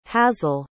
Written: hazell

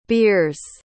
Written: biers

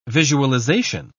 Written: visualization

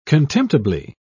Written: contemptibly

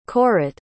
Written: korat

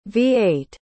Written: v-8

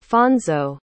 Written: fonzo

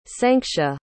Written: sanctia

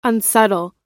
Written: unsubtle

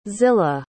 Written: zillah

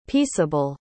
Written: pieceable

